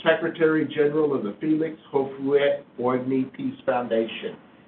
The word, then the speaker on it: Houphouët Boigny: If you know French real well, you can look at my writing and help me in pronunciations. He is very Houphouët Boigny. – Houphouët Boigny, you know that foundation? – Houphouët. – Houphouët. Boigny. Boigny. Merci beaucoup.